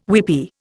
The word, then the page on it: weepy: Indian